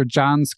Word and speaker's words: Can: He also has a great YouTube channel, Dr. John's Clinic. It's spelled C-A-N, but I just learned pronounced more like John.